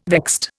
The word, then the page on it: vexed: Indian